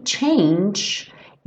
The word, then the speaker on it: change: Aqui, change, esse G final tem um som tch, como se fosse um DJ. Como o é E final do change não é pronunciado, a gente junta no A. Então, change a, change a hair for me.